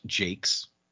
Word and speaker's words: Jacques: I'm a huge fan of the Red Wall series by Brian Jacques, or pronounced Jakes.